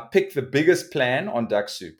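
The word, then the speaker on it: Dux-Soup: Now, there are a few of them called, like, ones like Duck Soup. I think that's how you say it.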